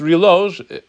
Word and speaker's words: reloj: Yes, reloj, reloj, I don't think you pronounce the J in Spanish, is the modern Spanish.